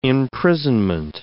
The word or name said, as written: imprisonment